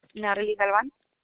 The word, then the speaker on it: Natalie: Natalie Galvan. – Can you spell it for me? – N-A-T-A-L-I-E and then G-A-L-V-A-N.